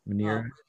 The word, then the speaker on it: Manyara: You know, the different park settings, and I forget how to pronounce Manyara. – Manyara.